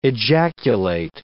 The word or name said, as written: ejaculate